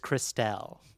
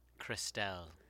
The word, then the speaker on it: Crystelle: It's spelled C-R-Y. S-T-E-L-L-E. – Crystal. – Her name is Crystal, but for some reason, I think she would correct you to be like, no, it's Christelle. I've never fucking seen this spelled. – Oh, probably. Christelle. I think you're right.